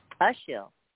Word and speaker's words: Ashil: Okay, this person's name is, and I hope I pronounced it correctly, is Sheil. You are live on the air with Psychic Coach. – Sheil. – It's Ashiel, actually. Ashiel, A-S-H-I-L. – Ashiel.